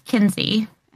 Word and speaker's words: Kinsey: So my real name is Kinsey, and it's spelled K I N S E Y.